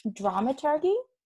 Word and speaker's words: dramaturgy: I do want to touch base because I feel like I'm going to get this dramaturgy. No, is that how you say it? – Dramaturgy, soft G. And then if you say you are a dramaturg, it's a hard G, or dramaturge.